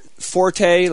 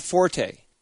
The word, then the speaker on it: forte: You know the word forte, like that's that guy's forte? – Sure. – Well, yeah, the actual correct pronunciation of that word is fort, not forte.